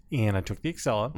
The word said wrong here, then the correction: Acela: Could you pronounce that again? – The Excella? – The Excella? – Is that not how it is? – You were writing a spreadsheet up to New York? – Excella? – Acela. The Acela. – A-C-E-L-A. – Acela. – Whatever it is. I was taking the train. – It's one C. It's not A-C-C-E-L-A, right? It's Acela.